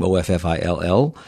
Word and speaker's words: Offill: That's how you say her name, Offill.